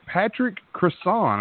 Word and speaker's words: Patrick Crossan: This is the coach of the Wizards District Gaming in the NBA-run NBA 2K League, Patrick Cresson.